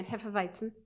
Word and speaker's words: Hefeweizen: I gotta say that again, Hefeweizen, because I said it incorrectly last time.